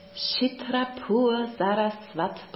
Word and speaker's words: Chitrapur Saraswat: Und was ich ganz spannend finde, ist, ich habe gelesen, du bist ein Mitglied der indischen Familiendynastie, der Chitrapur Saraswat Brahmanen. – Richtig. – Habe ich gut ausgesprochen? – Sehr gut, ja.